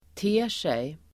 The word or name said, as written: ter sig